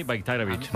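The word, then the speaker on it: Bajraktarević: O sea, la J la conviertes en una I, Bayraktarevic.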